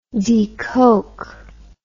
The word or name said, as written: decoke